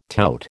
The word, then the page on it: tout: American English